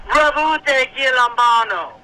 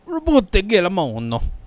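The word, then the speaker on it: Roboute Guilliman: It's Ribute Gilambano with heavy Italian accent. Talk to you later, guys. – Oh, I can do that. Ribute Gilambano.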